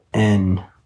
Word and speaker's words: Prolon: So it's as pro, I'm thinking of pronouncing it right. Prolon, Prolon. There it is.